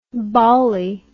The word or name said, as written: bawley